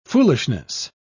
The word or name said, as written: foolishness